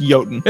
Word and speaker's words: Jotun: And it's pronounced Jotun. – Jotun? Okay. – Yeah, just like it's Mjolnir, not Mjolnir.